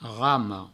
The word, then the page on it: rama: provençal; ramo